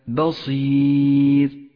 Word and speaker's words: بصير: انتبه إلى أن حرف الباء مرقق دائما، لاحظ ذلك في كلمة بصير، ب بصير، هذا نطقه الصحيح، وخطأ أن تقول ب بصير، فتنبه.